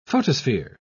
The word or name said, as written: photosphere